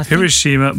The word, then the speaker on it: Hiroshima: I think Japanese words are pronounced without any stress on any syllable, so it should be Hiroshima.